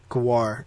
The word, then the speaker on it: Gwar: Gwar. It's just G-W-A-R, so Gwar.